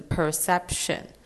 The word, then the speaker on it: perception: 那还有在下一个要注意的字就是 perception，它是有三个 syllable。它在那个 c 那边要发 s 的音。还有它中间那个 p，其实可以发得很轻微，做一个很轻的 p 音。